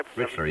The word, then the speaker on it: Richler: Richler, yeah, that's right.